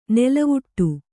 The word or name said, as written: ನೆಲೆವೀಡು